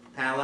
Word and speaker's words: Palau: How, can you pronounce his name for me? – It's Palau.